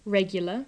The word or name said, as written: regular